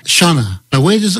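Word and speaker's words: Sionna: Yes, well, in true Irish style, you look at it and it sounds nothing like what it's spelt like. But Shunna is how you pronounce it.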